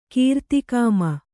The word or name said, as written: ಕೀರ್ತಿಕಾಮ